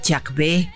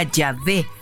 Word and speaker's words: Yahvé: Que nos escribe, no pone su nombre, pero dice, dice, dígale que no es Jack B, sino Yahvé se pronuncia Yahvé y es Yahvé de los ejércitos.